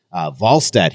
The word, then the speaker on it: Wallstedt: Wallstedt, he told us, is the way you pronounce his game, by the way.